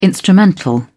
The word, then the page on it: instrumental: British English